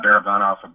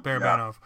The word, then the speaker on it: Barabanov: I, am really wondering about uh, I don't know how to say it, Babaranov. Bar- yeah, Barabanov. – Barabanov. Yeah.